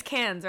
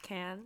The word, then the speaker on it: Cairns: It's Cairns, right? It's pronounced Cairns. – Yeah, it's spelled C-A-I-R-N-S. – Australians say, as if you're saying, like, soda can. Cairns.